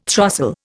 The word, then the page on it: throstle: Indian